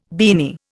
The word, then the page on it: beanie: Indian